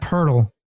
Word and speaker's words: Poeltl: Jacob Poeltl.